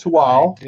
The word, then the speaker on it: Tehuatl: One of the Arkanos Islands, and it is called, it's called Tual, and it's spelled T-E-H-U-A-T-L.